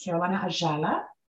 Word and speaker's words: Carolina Ayala: So if you would please help me welcome the beautiful Mrs. Carolina Ayala. She is a holistic life coach and a certified pranic healer. Did I say that right? – Yes.